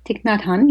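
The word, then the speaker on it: Thích Nhất Hạnh: When I read The Miracle of Mindfulness, written by, correct my pronunciation, Thich Nhat Hanh? – Thich Nhat Hanh, yes.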